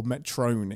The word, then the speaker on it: Metron: The observer that has put them there that Ortegas is screaming to and reveals themselves to be a Metron or Metrone, if you are, of the Kirk pronunciation school of pronouncing things.